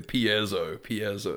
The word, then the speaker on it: piezo: I always said piezo. – Piezo? I have no idea how to pronounce this. – But no. People have schooled me and been like, no, piezo. – It is piezo, yeah.